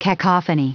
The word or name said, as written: cacophony